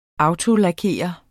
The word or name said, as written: autolakerer